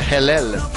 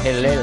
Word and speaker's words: halal: Ou une halal, mais qui se prononce en fait Hellel, normalement. Hellel.